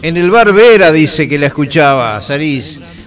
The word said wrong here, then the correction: Verá: Ah, Verá, con acento en la A.